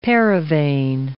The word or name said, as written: paravane